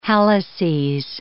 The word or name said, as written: halluces